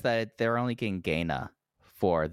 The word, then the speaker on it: Gaina: Gaina? G-A-I-N-A?